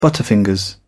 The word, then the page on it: butterfingers: British English